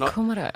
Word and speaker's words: kumara: But kumara. Kumara. Kumara is the right pronunciation.